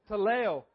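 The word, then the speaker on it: Tehillah: Talel. We're going to say Talel. It's T-E-H-I-L-L-A-H.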